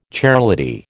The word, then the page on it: chairlady: American English